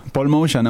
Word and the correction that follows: Motian: en vera, se pronuncia Motian perquè és descendent de gent d'Armènia,